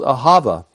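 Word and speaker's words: ahava (אהבה): The word is ahava. Hebrew is a breathing language. And so a lot of the words, letters, are pronounced with breath. And so ahava is pronounced ahava, ahava.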